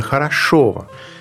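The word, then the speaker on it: Хорошёво: А название села Хорошова, правильно говорить именно Хорошова, также известного 16 века, принято связывать с красотой этих мест.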